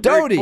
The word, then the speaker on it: Doughty: Judge Terry Doughty, I think that's how, is that how we pronounce his name? – I believe it's Judge Doughty, but you're Doty. All right, there we go.